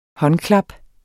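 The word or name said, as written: håndklap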